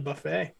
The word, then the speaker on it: buffet: What the silent T in buffet like that.